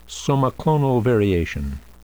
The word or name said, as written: somaclonal variation